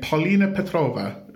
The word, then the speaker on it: Polina Petrova: You're playing Polina Petrova.